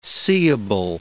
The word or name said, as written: seeable